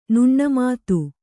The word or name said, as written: ನುಣ್ಣಮಾತು